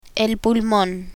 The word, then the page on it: pulmon: spanish